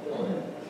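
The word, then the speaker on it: uilleann: So Ilan looks like Julian, that's pronounced Ilan.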